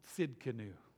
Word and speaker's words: Tzidkenu: Now this is a tough one, because the name of God here is Jehovah Tzidkenu. If you can say the word "let's," the last sound there, "let's," that's how you pronounce it, Tzidkenu.